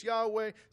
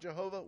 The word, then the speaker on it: Yahweh: And some say it's Yahweh, some say it's Jehovah. It's definitely not Jehovah. We know that much, okay, because there's no Hebrew sounds that make those noises, okay?